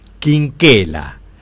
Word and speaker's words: Quinquela: fue adoptado por la familia Chinchela, como se escribe el apellido, dueños de una carbonería en el barrio de La Boca. El pintor adoptaría el apellido de sus padres adoptivos escribiéndolo como se pronuncia, Quinquela, y agregándole el suyo propio, lo que lo convertiría en un doble apellido, Benito Quinquela Martín.